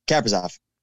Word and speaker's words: Kaprizov: And that first game of the year, Kaprizov, I still can't pronounce his name. Is it Kaprizov? – Kaprizov.